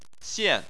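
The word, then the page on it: xian: xian4; tone 4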